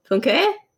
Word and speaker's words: Funke: Funke. Because you pronounce the E at the end, because she's German.